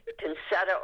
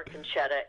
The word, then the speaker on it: Concetta: By the way, Concetta or Concetta is fine. We all know that Concetta is the correct pronunciation, mm-hmm. and Ed knows that. So, but a lot of people call me Concetta without it being the correct pronunciation, but I'll answer to anything.